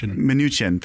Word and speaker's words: Mnuchin: We know a bit about Mnuchin's record. I hope I'm pronouncing that right. – Mnuchin. – Thank you.